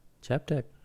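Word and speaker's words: Chaap Tech: I'm certain you're saying that wrong. – Chap, C-H-A-A-P, Tech. – What? Tech with a C-K? – T-E-C-H. – Okay. Chap Tech.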